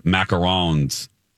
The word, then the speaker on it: macaron: I think I pronounced it wrong. Yeah, you're right, because coconut macaroons is what my mom loves. Macarons is what today is.